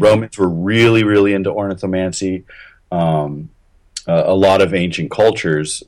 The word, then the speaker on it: ornithomancy: But let's get back to ornithomancy. Am I saying that right? Ornithomancy. Yeah, I think so.